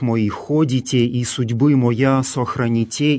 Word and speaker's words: сохраните: Здесь глаголы «сохраните», «сотворите», в которых мы слышим специфическое церковнославянское ударение, встречающееся именно в этих грамматических формах, второе лицо множественного числа настоящего времени, ударение на окончании.